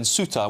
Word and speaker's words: Ceuta: I know that there's been reports of people in Ceuta, which I think is the correct pronunciation, as I've been told by James here on your say, who have been attacked by some of these young men as well.